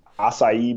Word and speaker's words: acai: I think the players are actually drinking some like, you know, Russell Wilson concussion water mixed with like acai berry. – Yeah. – Is that how you pronounce that? – I think you nailed it.